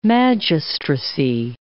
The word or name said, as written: magistracy